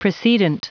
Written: precedent

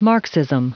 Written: marxism

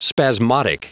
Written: spasmodic

